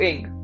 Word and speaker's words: Ng: I just absolutely hate it when I tell people no, it's not Andrew NG. Even I realize actually searched up how to pronounce his surname. It's actually pronounced Ing.